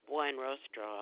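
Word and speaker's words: Buenrostro: Beatrice Juan Rostro. – That's B-E-A-T-R-I-C-E? – Yes. – And what's the last name? – Juan Rostro. I'll spell it for you. It's B as in boy, U-E-N-R-O-S-T-R-O.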